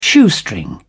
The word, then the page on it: shoestring: British English